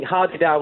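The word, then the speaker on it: Hadeda: I think hardy-da was the correct pronunciation, and it's a very commonly seen bird around South Africa.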